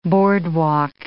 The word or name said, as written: boardwalk